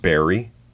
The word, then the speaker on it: Bury: It's, I guess, kind of pronounced Barry, but it's spelt B-U-R-Y.